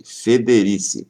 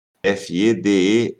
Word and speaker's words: Federici: Eu estou falando da maneira que se escreve. Federici. Quase igual Feder, né? Federici. Então, Silvia Federici.